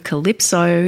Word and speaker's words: CLYPSO: Up next, I have a track by a Sydney-based producer and vocalist by the name of Calypso. It's spelled C-L-Y-P-S-O in capitals.